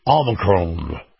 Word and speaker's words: Omicron: Now, got to talk about Omicron. Am I saying that right? – Yeah.